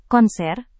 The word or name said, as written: konser